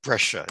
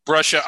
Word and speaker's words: Brescia: Titel's told me it's pronounced Brescia. Is that right? Brescia? – Brescia. Excuse me for mispronouncing it.